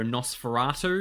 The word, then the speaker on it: NOS4A2: Um, well, I uh, checked out the first couple episodes of the TV show Nosferatu because the second season just came out. – Oh, right. – It's spelled NOS4A2. It's a number plate and it's based off the Joe Hill novel Nosferatu.